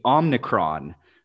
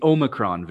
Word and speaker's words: Omicron: I saw his speech about it, and he called it the Omicron variant, but it's the Omicron variant.